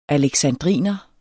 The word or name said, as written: aleksandriner